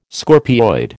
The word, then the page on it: scorpioid: American English